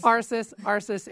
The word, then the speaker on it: ARSIS: The program is continuing at Boise State University as the Augmented Reality Space Informatics System, or ARIS. Did I say that right? – ARSIS, ARIS. – You know, I knew I'd get it wrong.